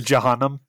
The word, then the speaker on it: Jahannam: So heaven being called Jannah, and also hell is called Jahannam. I might be butchering that. I apologize. Jahannam. – There's Jahannam. – That's it. Yep, you got it.